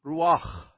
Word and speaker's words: ruach: And even that word, you have to breathe out, and it's ruach. That's how you say it.